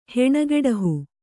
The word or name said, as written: ಹೆಣಗೆಡಹು